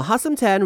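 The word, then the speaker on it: Hustomten: It's spelled H-U-S Hus, T-O-M Tom, T-N 10. Now everyone has heard of the Christmas elf, but what about an evil one? Now Hustom 10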